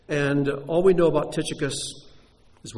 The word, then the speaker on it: Tychicus: In Greek, it's pronounced Tychicus. That's why we say Tychicus instead.